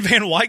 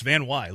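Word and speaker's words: VanWey: It's V-A-N-W-E-Y. I don't know why that's weird, but it's pronounced weird, really. Because it looks like Way, but it's pronounced Y. It's Van Wy, Logan Van Wy.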